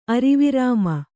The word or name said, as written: ಅರಿವಿರಾಮ